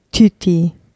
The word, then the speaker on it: Tytti: It's spelled T-Y-T-T-I, but pronounce it like Tutti. Like, you know, you with an umlaut.